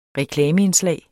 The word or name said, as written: REKLAMEINDSLAG